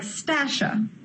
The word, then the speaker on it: Stasha: It's my first name, Stasha, and that's spelled like Stasha. S T A S H A well at Stasha at Q Media Productions,